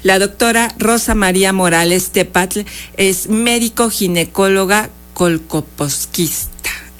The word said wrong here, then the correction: colposcopista: No, colposquista. Bueno, discúlpeme usted, pero bueno, hoy estoy medio trabada. Colposcopista. – Así es. – Ah, ya lo dije bien.